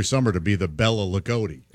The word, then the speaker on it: Loogootee: L-O-O-G-O-O-T-E-E. – Ligoti? – Ligoti, Indiana. – Ligoti, Indiana.